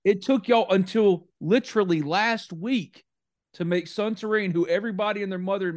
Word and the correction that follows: Suntarine: in Mississippi, not just me, Suntarian. – Suntarian, sorry. Sonny P. we've been saying it for a year. I've been calling him saying his name wrong for a year, but we've been saying it for a year that Sonny P is a five star prospect.